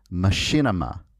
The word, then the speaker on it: machinima: And that word is machinima. That is spelled M-A-C-H-I-N-I-M-A.